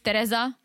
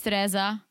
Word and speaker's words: Teresa: As you guys know, my name is Teresa. This is the way, Czech way to pronounce it is Teresa. The American way is Teresa. I'll roll with Teresa, right?